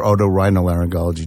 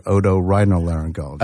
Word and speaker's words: otorhinolaryngology: So that's otolaryngology or otorhinolaryngology, depending upon Wait, say it again. Otolaryngology or otorhinolaryngology.